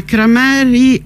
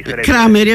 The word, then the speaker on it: Crameri: Allora, siamo, come detto, in collegamento telefonico con Alan Crameri, ho detto bene? – Buongiorno, sì, Crameri. – Crameri, vabbè.